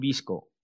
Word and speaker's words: VSCO: Algo parecido pasa con Visco, que se escribe VSCO.